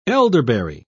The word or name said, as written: elderberry